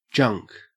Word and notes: junk: Australian English

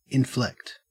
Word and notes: inflect: Australian English

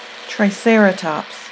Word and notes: triceratops: American English

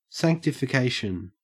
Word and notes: sanctification: Australian English

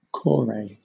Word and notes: kore: British English

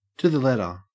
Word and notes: to the letter: Australian English

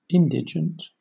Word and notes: indigent: British English

